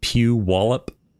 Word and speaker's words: Puyallup: It's you pronounce it Puyallup.